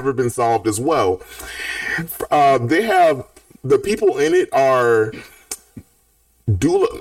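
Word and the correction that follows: Duela: I'm sorry, did you know that Dula Dent's name is Duella Dent? Her name is pronounced Duella.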